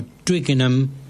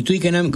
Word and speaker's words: Twickenham: En el caso de Tottenham y Twickenham, como terminan en enam, es clavado que no se pronuncia, no se aspira la H.